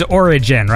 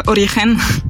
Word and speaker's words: origen: It's a Spanish word, so it would be more like a origen, but we can say how or we can, you know. – Wait, so I'm supposed to soften the G? – Yeah. Origen.